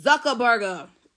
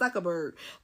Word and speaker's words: Zuckerberg: Y'all know how he pronounced it Zuckerberg. Not Zuckerberg, but Zuckerberg.